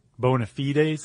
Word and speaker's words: bona fides: But if it is your bona fides, like a noun, like your credentials, mm-hmm. – then that's how it's pronounced.